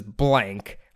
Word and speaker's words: Blanck: I believe this is blank, but it's spelled B-L-A-N-C-K. But that's the only way you can say his last name, right? – Yeah.